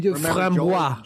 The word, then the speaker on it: framboise: Well, normally I get the framboise with, or framboy, whatever.